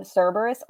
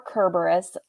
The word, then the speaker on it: Cerberus: I thought, and we can pronounce its name Cerberus or Kerberus. It can be pronounced either way.